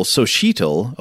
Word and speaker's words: Xochitl: Mexicas, Mayans, Olmecs, have the letter X written and mostly pronounced as S at the beginning of a word, or SH in the middle. For example, Xochitl, a woman's name, Mijote, some pit barbecued dish, Xochimilco, a place near Mexico City.